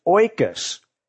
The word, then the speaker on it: oikos: and it's pronounced oikos. Let's say that together. That's a really cool word. Oikos. Now, it's kind of like the noise that a pig makes when it snorts, but it's a little different.